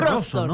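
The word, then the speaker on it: Rawson: Debería ser Rosson, ¿no? La pronunciación.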